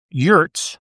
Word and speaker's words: yurts: For instance, if I wanted to become the number one real estate agent in North America, specializing in yurts, is that how you pronounce it? – Yes.